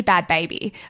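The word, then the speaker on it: Bhad Bhabie: Oh, by the way, I accidentally pronounced bad Barbie and it's actually bad baby.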